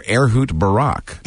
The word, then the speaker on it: Ehud Barak: What's the correct pronunciation? – I believe it's Israeli Defense Minister Ehud Barak, and he's here now.